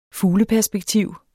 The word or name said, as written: fugleperspektiv